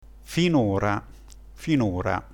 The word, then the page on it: finora: dialetto